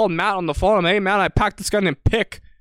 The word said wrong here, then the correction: Piqué: His card's blue. And then Matt's like, you idiot. – That's Gerard Piqué.